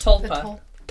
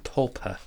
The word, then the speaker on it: tulpa: Tulpa. – Tulpa.